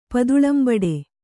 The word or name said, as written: ಪದುಳಂಬಡೆ